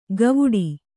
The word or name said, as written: ಗವುಡಿ